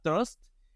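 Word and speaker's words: TR/ST: Otro de esos proyectos que me gusta mucho y que hasta hace poco supe cómo se pronunciaba era Trust, pero que se escribe TR-ST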